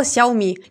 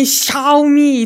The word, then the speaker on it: Xiaomi: Лидером по количеству ввезенных в Россию смартфонов стала Xiaomi. Не к Xiaomi, не Xiaomi и так далее, а Xiaomi. Пора уж запомнить, как произносится.